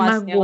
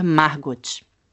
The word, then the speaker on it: Margot: É, mas eu, sabe que eu pronunciei errado meu nome, né? Porque meu nome, na verdade, é Margot. Margot. Vem... Sim, porque ele vem da, do alemão. No alemão, ele é Margot.